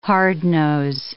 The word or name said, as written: hardnose